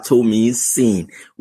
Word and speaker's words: Tomisin: My full name is Oluwa Tomisin, which means God is worthy of worship.